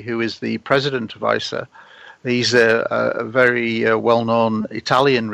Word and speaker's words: ICER: Do we call it ICER? Is that that's perfect pronunciation, ICER.